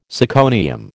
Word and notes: syconium: American English

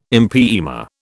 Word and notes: empyema: British English

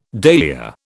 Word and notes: dahlia: British English

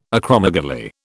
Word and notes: acromegaly: British English

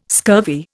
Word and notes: scurvy: Indian

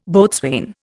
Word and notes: boatswain: Indian

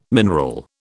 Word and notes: mineral: British English

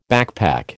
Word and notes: backpack: American English

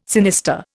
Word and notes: sinister: Indian

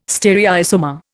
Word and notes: stereoisomer: Indian

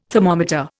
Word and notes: thermometer: Indian

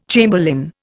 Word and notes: chamberlain: Indian

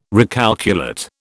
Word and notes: recalculate: British English

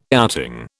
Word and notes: outing: British English

